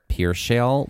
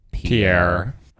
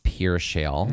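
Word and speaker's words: Pierre Shale: The major ones that are talked about the most often are pier shale, which is spelled like Pierre. – But it's pronounced pier shale.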